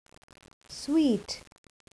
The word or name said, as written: suite